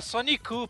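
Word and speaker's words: Sonic-u (ソニック): Não, ele fala Sonic não, ele fala Sonic-u, porque aí tá em japonês.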